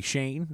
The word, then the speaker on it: Schoen: You assume it's pronounced like a Jewish name. But it's not. It's apparently Schoen, and that's Midwestern.